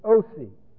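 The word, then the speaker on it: Osee: Now, who is Osi? Osi is Hosea, which is a form of Yehoshua. Hosea, we spell it. There's no way, there is no H in Greek, so you just put a rough reading like this, and you have then sort of a transliteration or attempt at it, O-C.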